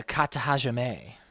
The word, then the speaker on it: Kata Hajime: The Kata Hajime. – Kata Hajime. And he wins with the Kata Hajime, which is illegal in judo, but legal in wrestling.